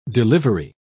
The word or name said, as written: delivery